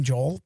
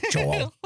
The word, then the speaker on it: Joel: And sometimes, and when I can always tell when she's like correcting me or mad at me because my name, Joel, becomes two syllables. Joel. Joel. So yep.